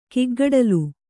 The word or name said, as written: ಕಿಗ್ಗಡಲು